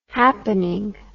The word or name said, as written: happenin